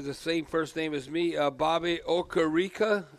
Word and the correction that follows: Okereke: uh, Riki, or how do you say his uh, name? Bobby Okereke.